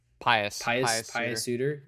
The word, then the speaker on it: Pius Suter: Like we said, Pius Suter? Is that how you say his name? – Pius Suter.